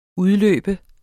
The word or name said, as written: udløbe